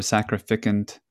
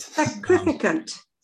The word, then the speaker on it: sacrificant: A sacrificent or a sacrificant? Sacrificent. – Sacrificant. – Sacrificant. Okay.